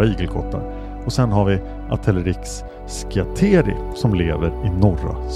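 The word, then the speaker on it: Atelerix: Ett släkte är Atelerexis. Förekommer huvudsakligen i Afrika. Och i nära släkt med arterna i Erinaceus. Bland Atelerex, så uttalas det, förekommer afrikansk pygmeigelkott och Atelerex frontalis som lever i södra Afrika från Angola och Zimbabwe till Sydafrika.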